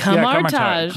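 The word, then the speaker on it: Kamar-Taj: I just said Kamartage, but I don't know if that's correct. – Kamartage. Yeah, Kamartage is how they pronounce it, yeah.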